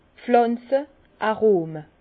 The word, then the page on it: d' Pflànze, Arome: Bas Rhin; Herrlisheim